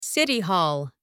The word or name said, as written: city hall